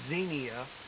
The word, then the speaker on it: Xenia: And, yeah, I think it's pronounced Xenia. Kind of like there was a TV show, Xena, Warrior Princess, something like that. So, anyway, long E, It sounds like it starts with a Z, Xenia.